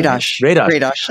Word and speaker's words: Radosh: And I know that you're going to be talking with, on your podcast, Beg to Differ, you're going to be talking with historian Ron Radosh. Is that how you pronounce the name? I'm sorry. – Radosh. That's fine.